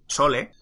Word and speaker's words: sole: Pues porque lenguado en inglés se dice soul, sole, pero se pronuncia igual que soul.